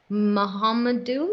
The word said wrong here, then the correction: Mukhamadullin: Close, Mukhamadullin. – Mukhamadullin.